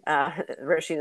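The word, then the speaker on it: Róisín: And I was reminded of this week when this Roisin Murphy, Roisin is how you pronounce her name, was canceled for it.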